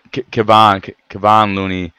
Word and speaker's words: Kevon Looney: I mean, Kevin Looney, Let me make sure I'm saying his name correctly. Yeah, Kevon Looney.